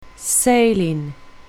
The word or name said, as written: sailing